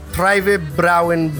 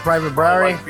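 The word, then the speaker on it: Privatbrauerei: Captain says it's Private Brauery. – Private Brauery. – Yes, Private Brauery.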